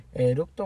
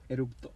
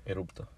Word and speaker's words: eructo: ¿Eructo o erupto? ¿Cómo se dice? – Eructo. – Erupto. – Eructo. Me salió el eructo.